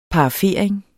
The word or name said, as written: parafering